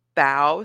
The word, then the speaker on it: bao: Bow, so it's like. – Are you pronouncing that correctly? – Bow. Bow. Bow. You're right. It's bow. – Bow. – Bow. – I still don't know what you're talking B-A-O.